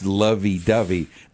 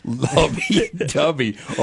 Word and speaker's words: Lovey Dovey: It rhymes with love. – Yeah, but... – And doves are associated with love. – Yeah, but that's... – It's Lovey Dovey, not Lubby Dubby.